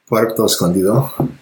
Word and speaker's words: Puerto Escondido: Puerto Escondido.